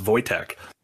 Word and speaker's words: Wojtek: But Wojtek was one of the people, Wojtek, and I know it's pronounced Wojtek because there's a famous bear during World War II.